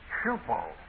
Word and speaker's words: hupo: The Greek prepositions are very interesting, and there's a Greek preposition, hupo. I'm giving you the English pronunciation, H-U-P-O.